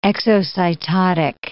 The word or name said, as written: exocytotic